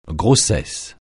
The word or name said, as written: grossesse